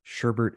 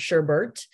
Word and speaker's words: sherbet: Yeah, this may be a little technical, but I was wondering, can you describe the difference between sherbet and sorbet? – I'd love to. One is commonly mispronounced as sherbert, and one is not.